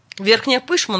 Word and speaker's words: Верхняя Пышма: Как правильно звучит? Верхняя Пышма или Верхняя Пышма? – Верхняя Пышма, насколько я знаю.